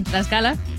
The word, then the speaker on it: Tlaxcala: También le mandamos saludos para Pérez Mario y para el tío Toño, la gente de Mazapán, Tlaxcala. Ahora sí lo pronuncié bien, Tlaxcala.